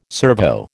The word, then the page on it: servo: American English